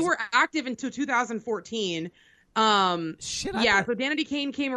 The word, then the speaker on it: Danity Kane: Am I saying that right? Danity Kane. – Danity Kane. Yeah.